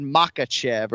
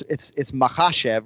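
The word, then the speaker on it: Makhachev: Well, he kind of said Makachev or whatever. It's Mahachev, right? So you're emphasizing the second syllable, and that'll be our broadcast focus this weekend.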